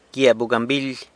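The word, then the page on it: gyia bugambily: San Lucas Quiaviní Zapotec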